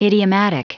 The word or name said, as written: idiomatic